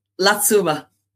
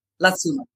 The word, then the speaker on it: Latsuma: Apparently, the locals told me that if you just say Satsuma, then Latsuma is the correct pronunciation. Okay. So, Latsuma.